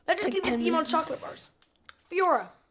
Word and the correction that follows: Fiori: Fiori. Sorry, Fiori. I mispronounced it.